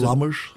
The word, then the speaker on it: Lammers: jeg ved ikke, hvordan udtaler det. – 100%. Lammers.